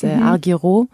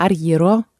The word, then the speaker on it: Argyro: Und mein Vorname kommt aus dem Griechischen, der ist Argyro, wie man ihn auf Deutsch ausspricht, aber Argyro eigentlich auf Griechisch.